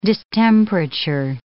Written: distemperature

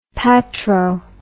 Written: patro

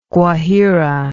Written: Guajira

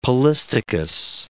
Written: polystichous

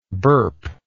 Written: burpless

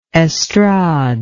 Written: estrade

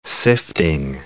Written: sifting

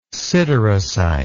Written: siderocyte

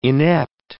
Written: inept

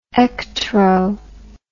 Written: ectro

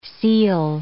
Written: ceil

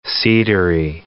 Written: cedary

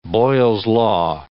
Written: Boyle's law

